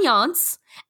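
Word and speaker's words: Jontz: No, the news anchor's name is Ron Yontz, spelled with a J. – Yeah, but pronounced with a Y. Ron Yontz, and he is a best supporting actor in this movie.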